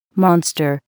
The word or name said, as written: monster